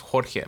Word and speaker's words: Jorge: My name is pronounced Jorge, but they never say Jorge. They say George. Because Jorge is just like another term of saying George in Spanish.